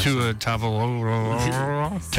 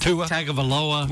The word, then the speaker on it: Tua Tagovailoa: Tua Tagovailoa. – Tua Tagovailoa.